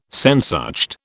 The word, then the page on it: Sehnsucht: British English